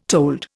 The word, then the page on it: told: Indian